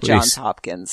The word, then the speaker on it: Johns Hopkins: Nine out of ten people say Johns Hopkins. – No. There are at least three Johns at Johns Hopkins, so you do have to pronounce Johns Hopkins, thank you.